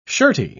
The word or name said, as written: shirty